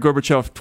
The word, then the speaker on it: Gorbachev: Gorbachev, is that how you say it? – Yes, I actually encountered Gorbachev twice,